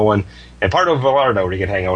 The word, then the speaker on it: Vallarta: A Vallarta. Vallarta, Scott.